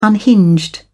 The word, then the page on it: unhinged: British English